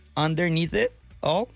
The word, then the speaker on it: Underneath It All: Underneath It All, sí.